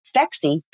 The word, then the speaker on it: Phexxi: So Phexxi, P-H-E-X-X-I, is because the pH is the mechanism of action. And then the double X chromosome is for women. And it doesn't hurt that it rhymes with sexy.